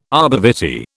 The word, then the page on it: arbor vitae: British English